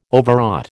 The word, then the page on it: Overwrought: American English